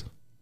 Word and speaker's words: Amidan: So at the same time, all right, we're going to say your last name at the same time on three. Ready? One, two, three. Jackass. Amidan. – Yep. – She's right. I was saying it wrong.